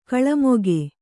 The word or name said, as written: ಕಿಳ್ಮೊಗ